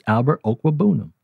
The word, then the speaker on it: Okwuegbunam: They mostly call him Albert O. Got to put some respect on that man's name. Say his name. It's not Albert O. It's Albert Okwuegbunam. That's easy.